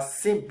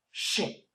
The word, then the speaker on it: Shin: I am a Sin Buddhist. The officer looked at me puzzled. I managed to pass through the immigration. I was so tired. I realized later I should pronounce Shin, not Sin.